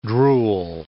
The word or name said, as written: drool